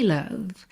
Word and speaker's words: love: Vamos aproveitar que é dia dos namorados, que nós estamos falando de amor, para caprichar na pronúncia dessa palavra que não é love, não é love, é love. Love. L-O-V-E. Bom, o E não é pronunciado.